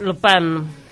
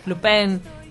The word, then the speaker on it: Lupin: Estoy viendo Lupin, se pronuncia en francés. Lupin. Lupin.